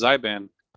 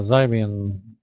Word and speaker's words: Xbian: How do you say that? – I think it's pronounced Xbian. – Yeah, Xbian.